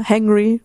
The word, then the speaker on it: hangry: Wenn Hunger zu Wut führt, im Englischen gibt es dafür den Begriff hungry. – Ja. – Spricht man das so aus? Also eine Mischung aus Anger und Hungry. – Ja, hangry sagt man auch, aber hangry, ist egal.